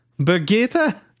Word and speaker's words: Birgitta: I don't think that's how you say that. – Brigitte? – I don't know. It's a Swedish name, but Brigitte. I don't know how to say it. I think that one's the closest. – Birgitta Lindholm.